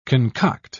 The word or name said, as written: concoct